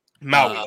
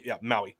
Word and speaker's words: Maui: But these wildfires that's going down there in Maui, I don't know how you say it, but I'm just Maui. – Okay. Maui. They say that this is the biggest wildfire in over 100 years that is in since recorded history, pretty much, really.